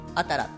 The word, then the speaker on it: 新しい: まあ、新しいと書いてあたらって読むんだけれども、はいはい、まあ、そういうファーストシングルなので新しい。